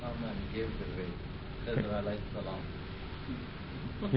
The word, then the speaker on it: Khidr: Khezr is spelled K-H. Khezr. Or Khezr. K-H-I-D-R.